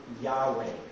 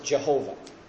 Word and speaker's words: Yahweh: the one represented by the L-O-R-D in all caps in our Bibles, when you see it written this way, that represents the name Yahweh. Some pronounce it Jehovah. But to say Jehovah is just taking the vowels from the name Adonai, which means master or Lord, and using those vowels to pronounce this name. And so that's the way that arose. It's a non-existent name.